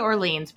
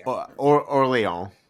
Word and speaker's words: Orléans: How do you say it? I say Orleans, but you said it differently. – It's Orléans. – Orléans. – But you can say Orleans. As long as people know what you're saying, like, that's That's always my stance with like.